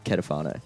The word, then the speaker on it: Ketafono: Joined today for the school holiday special, Anthony De Lorenzo and Jacob Ketafono.